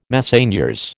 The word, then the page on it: Messeigneurs: American English